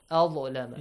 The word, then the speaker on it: أظلم: Wait, أظلم pronunciation is it's a heavy letter. أظلم.